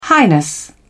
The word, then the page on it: highness: British English